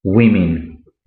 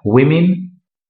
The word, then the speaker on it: women: Woman, women. Yo sé que no tiene mucho sentido la pronunciación, pero es así. Woman, una mujer, women, mujeres en plural.